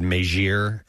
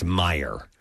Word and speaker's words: Meijer: A lot of people are texting and saying I mispronounced, I said Meijer is the name of the store. It's Meijer.